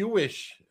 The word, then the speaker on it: Hewish: We have Chris Hewith, who, did I say that right? Hewith? – Hewish, yep, you got Hewish. It. – Hewish, who is the big boss over here at Ixola.